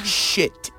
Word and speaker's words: shit: Porque la palabra, first of all, tenemos que hablar un poco de la pronunciación. Porque hay la palabra mierda en inglés es shit, shit. Y también hay la palabra que es para sábana, que es sheet Sheet. So, tenemos que shit. Este vocal, esta vocal es muy difícil de pronunciar para los hispanohablantes.